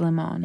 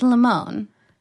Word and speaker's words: Limon: Because I went to school with this girl, and her last name was spelled... Lemon. – Mm-hmm. – But you didn't pronounce it lemon. – It's Limon. – It's Limon.